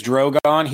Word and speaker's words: Drogon: It is Drogon. He was named after Khal Drogo, okay. In honor of obviously Daenerys's first husband. – Right. Drogon is, and I know JC has been tweeting about this.